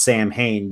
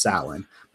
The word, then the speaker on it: Samhain: but it's spelled Samhain. So it looks like it says Samhain, but it's pronounced Samhain.